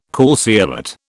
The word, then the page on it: calceolate: British English